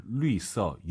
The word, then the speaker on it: 绿色: 아하, 绿色, 유땡땡. L 유땡땡, 리요 할 때 그 발음이긴 한데 성조가 다르긴 하죠.